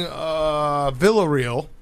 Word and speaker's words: Villarreal: and they were playing Villarreal.